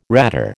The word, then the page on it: ratter: American English